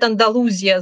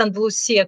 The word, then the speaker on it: Андалусия: И на самом деле, если мы говорим об Андалусии, то некоторые люди говорят «Андалузия» с буквой, со звуком «з». Другие говорят «Андалусия» с «с», а другие, третьи, говорят «Андалусия», как я, потому что это правильное ударение именно в испанском языке.